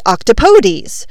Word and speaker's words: octopodes: The Greek ending would then be octopodes. O-C-T-O-P-O-D-E-S. So, octopodes? I do kind of like Merriam-Webster's reminder on how to pronounce octopodes. They say it rhymes with, don't say that please.